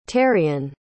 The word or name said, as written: terrian